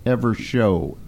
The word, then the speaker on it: Evershow: Evershow, S-H-O-W.